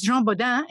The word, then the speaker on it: Jean Bodin: Of course, Jean Bodin, and your pronunciation was perfect, is principally known as a political philosopher, not a natural philosopher.